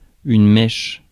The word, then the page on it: mèche: France; /mɛʃ/